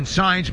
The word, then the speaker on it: Saenz: Now, the new pitcher, lefty Dustin Saenz, S-A-E-N-Z, pronounced Saenz, and his fastball at 93 swung on a missed strike one to Joey Meneses.